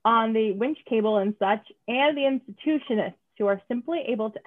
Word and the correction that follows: Intuitionist: Oh, wait, am I pronouncing that wrong? Intuitionist.